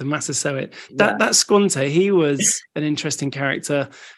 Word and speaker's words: Squanto: I was reading, there's one really important one, isn't there, called Squacho? – Yeah, Squanto and Samoset.